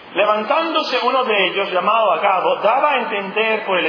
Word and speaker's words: Agabo: ¿Así se pronuncia Agabo? ¿O tiene acento? No puede ser Agabo, ¿o sí? Agabo, muy bien, Agabo.